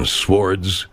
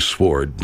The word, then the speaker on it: sword: Marvelous steel shields and swords, and that's the pronunciation that I prefer, by the way, sword, not sword, because sword sounds like sore, and when I hear that word, I am thinking about sores.